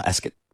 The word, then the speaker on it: Ascot: Am I saying it correctly now? Ascot. – Yeah, Ascot. – It's like biscuit. – Ascot. Biscuit. Yeah, yeah, okay.